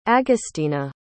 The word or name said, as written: agostina